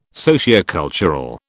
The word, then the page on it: sociocultural: British English